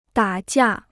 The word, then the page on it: 打架: dǎ jià